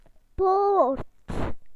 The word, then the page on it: gate: British English